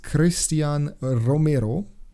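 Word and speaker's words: Cristian Romero: Gut, dann äh, fange ich an mit Christian Romero. Oh ja. Den muss man so ja, aussprechen.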